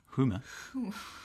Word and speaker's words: Huma: Huma? How do you spell that? – H-U-M-A.